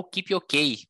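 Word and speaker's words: Kipyogei: Então vamos lá, no feminino, a Diana Kipiogay, ou Kipiokei, como ela disse que prefere a pronúncia do nome dela depois da prova, é, ganhou a prova, ela com 2 horas 24 45, era a terceira maratona que ela estava participando na vida dela.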